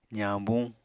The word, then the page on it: zañbuñ: apatani